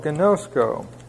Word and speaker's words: ginosko: And then we also have another Greek word to know, ginosko. And these are both long O's, G-I-N-O-S-K-O.